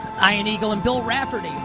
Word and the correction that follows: Raftery: with the call this weekend. Raftery? I pronounced it incorrectly.